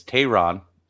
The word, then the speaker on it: Teheran: I got a nickname because we've been saying his last name wrong. It's Teheran.